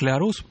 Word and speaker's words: Ларус: он произносится как Ларус, это французское слово, но наша большая советская энциклопедия узаконила его как «Ларус» через «А». Поэтому правильно говорить, и мы пишем так и в нашей книге, «Ларус» с двумя «С» на конце. Это фамилия Пьера Ларуса,